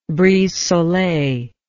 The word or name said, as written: brise soleil